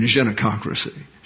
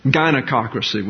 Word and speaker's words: gynecocracy: And that's the British pronunciation, genococracy. See? But gynecocracy would be the American version.